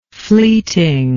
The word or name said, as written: fleeting